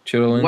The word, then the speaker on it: chitterlings: They called them chitterlings, but then that just in that chitlins? – Chitlins? – Is that how that's spelled? – No. Not cheddar. – Oh, that is how it's spelled. – It is. – Chitlins. – Wow. – Chitlins? – I've only known them as chitlins, so I didn't think that's how it was spelled.